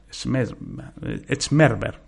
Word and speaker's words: Schmerber: Para valorar, por otra parte, la impermeabilidad, se utiliza el test de columna de agua o Schmerber, Schmerber por, pronunciarlo en buen alemán.